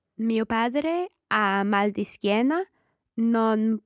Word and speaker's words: padre: padre. – Francesca, let's hear padre. – Padre. – Okay, it's a strong D in there. And just like dormire, padre.